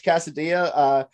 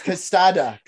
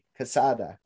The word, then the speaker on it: Quesada: Judge Casadilla. I said that wrong. It's not Casadilla. – Delicious. – Casada. I don't know how to say it. Q-U-E-S-A-D-A. Casada.